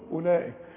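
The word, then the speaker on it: أولئك: اذا لقيت الخمسة على الحروف دي يكتب هذا الحرف لا ينطق، زي اللي معانا دي اولئك، ها، السادة المشاهدين شايفينه حتى باللون الرمادي، اللي معاه مصحف ملون هيشوف الواو دي باللون الرمادي، يبقى الغي الحرف ده يكتب ولا نقول اولئك.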